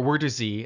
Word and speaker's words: Wörthersee: So essentially, think of Wörthersee as the or Wörthersee, as it's properly pronounced.